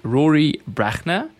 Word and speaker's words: Brachner: Today I have with me Rory Brachner.